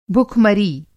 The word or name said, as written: भुखमरी